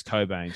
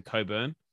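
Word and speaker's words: Cockbain: Why are the BBL commentators pronouncing Cockbane as Cobain? Surely the CNK aren't silent.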